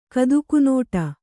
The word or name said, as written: ಕದುಕುನೋಟ